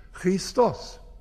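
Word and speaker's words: Christos (Χριστός): Christus ist ein Fremdwort. Es ist ein griechisches Wort, deutsch ausgesprochen. Das griechische Wort heißt Christos. Und Christos heißt Gesalbter zu deutsch.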